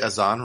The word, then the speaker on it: Azan: Did I at least get Azan right? – Yeah.